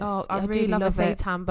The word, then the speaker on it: seitan: Especially when you've got, I always say it wrong, is it seitan? – Seitan, yeah.